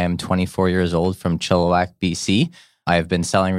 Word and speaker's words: Chilliwack: He is a realtor at Century 21 Creekside in Chilliwack. Did I get that right? – Yeah, Chilliwack.